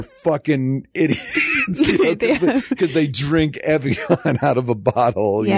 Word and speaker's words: Evian: Evian? – Yeah.